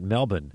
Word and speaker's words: Melbourne: And so I landed in Melbourne, although it's spelled Melbourne, but Australians will always say, oh, man, you Americans can never pronounce it right. It's pronounced Melbourne. – I actually never knew that, Melbourne.